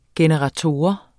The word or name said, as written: generator